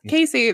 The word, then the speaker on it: Casey: Cass- Casey, the, the keep on calling her Cassie because we didn't know how to pronounce it till she came on like FaceTime just one time, and I'm like, oh man, I'm an asshole. I've been saying it wrong.